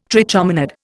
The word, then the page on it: trichomonad: Indian